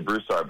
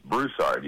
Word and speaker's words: Broussard: I say Broussard, but it's Broussard. You hit the first syllable, and my cousins and my uncles just sneer at me for my Yankee pronunciation.